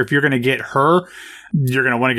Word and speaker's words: H.E.R.: her name is H.E.R. is pronounced her.